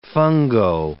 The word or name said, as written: fungo